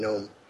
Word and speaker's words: gnome: because of the silent G in gnome.